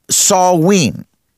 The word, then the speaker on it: Samhain: And we've been covering that now, or Samhain is how you pronounce it. You spell it Samhain.